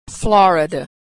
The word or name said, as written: Florida